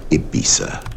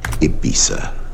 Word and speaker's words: Ibiza: Она на английском произносится как Ибиса. – Hoaxes were hatched. Ibiza, Ibiza, Ibiza, Ibiza, Ibiza.